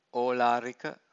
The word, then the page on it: olarika: Pali